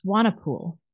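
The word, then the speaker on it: Swanepoel: I went to high school with Candace Swainpool. It's pronounced Swanapool.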